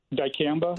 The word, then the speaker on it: dicamba: Dicamba? Is that how they say that? – Dicamba.